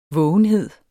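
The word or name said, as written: vågenhed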